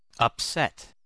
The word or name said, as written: upset